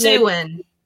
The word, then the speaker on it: Siouan: Siouan. – Huh? – Siouan. – The Siouan? – Sioux. Yeah. – How do you, oh, Sioux. – Siouan language. Yeah, it's the Siouan language. – So it's the Siouan language speaking tribe closely related to the Monacan Nation.